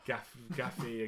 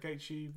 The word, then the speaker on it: Gaethje: Gaethje and what he could do, or if you're pronounce it in the way that Sky Sports presenters do, as Gaffy and Gafja and whatever.